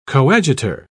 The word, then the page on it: coadjutor: British English